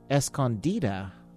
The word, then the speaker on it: Escondida: You're listening to Jolie Holland off her album Escondida. Escondida? Wow, I've been saying it wrong all these years.